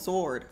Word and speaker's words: sword: If you can hold a teddy bear, you can hold a sword. – Sword. I think the W is silent.